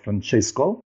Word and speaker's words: Francesco: or less Francisco, but working with some colleagues abroad made me say Frank, which is the same of Francesco, because they were saying Francesco with this kind of accent, like Stan Laurel and Oliver Hardy movies.